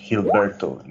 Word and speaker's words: Gilberto: It's pronounced Gilberto in Spanish. It's Mexican.